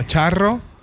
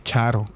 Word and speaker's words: Charo: este No, eh, no, Charro. – Charo, Charo. – Sí, no, no, pero ya lo pronuncian mal en el. Obviamente, como no. O exagerado. – Ajá, el, el, nombre lo cambian y ya was like, es Charo. Just Charo, Charo.